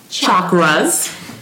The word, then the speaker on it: chakras: Now, you know that we like to talk about the chakras. – I'm training. – That you say it like chocolate, which are spinning wheels, these energy vortexes inside of your body There are seven.